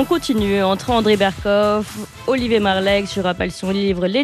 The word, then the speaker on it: Marleix: Olivier Marleix. – Marleix.